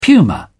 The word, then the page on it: puma: British English